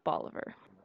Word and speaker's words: Bolivar: So I had to make sure that I was pronouncing it correctly, but it is Bolivar.